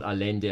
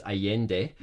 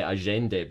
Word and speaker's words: Allende: Like, for example, the guy who plays for Sundowns, the Uruguayan, it's not Allende, it's Allende, or some say Agende, because that's how you pronounce the double L in Spanish.